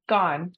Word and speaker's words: Guan: I am privileged today to be sitting with Kimberly Guan. Am I saying that right, Kim? Guan? – Gone. Like gone. See you later. Just see you gone. Later. Just like see you later, gone.